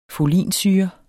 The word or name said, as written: folinsyre